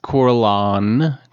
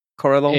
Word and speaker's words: Corlon: There's nothing in here that gives us any insight to why or what they were worshiping, besides Corlon. – The N is silent. Corlon. – Yeah. Corlon. – Kind of French sounding, maybe.